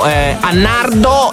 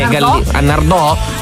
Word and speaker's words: Nardò: Nell'Alto Salento, Annardo e Annardò? Si sì, c'è l'accento, dice.